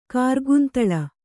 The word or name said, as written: ಕಾರ್ಗುಂತಳ